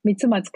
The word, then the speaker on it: Mitsumatsu Kayoko: How would you say it? – Mitsumatsu Kayoko. – Oh, yes. Kayoko comes at the end.